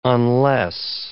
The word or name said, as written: unless